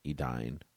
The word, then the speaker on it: Edain: A I has the sound of the English I, thus the second syllable of a dine is like the English dine, not Dane. E D A I N, edine.